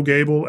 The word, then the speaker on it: Goebel: And then Bo Goebel at Riverside Investment Group. It's a little bit weird spelling from the way that we pronounce our last name, but I'm sure Eileen will include it in the show notes.